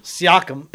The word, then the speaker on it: Siakam: How's it pronounced? – Siakam? – Siakam. – S-I-A-K-A-M.